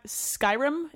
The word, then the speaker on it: Skyrim: Elder Scrolls V Skyrim," is that how we're pronouncing this? – Correct.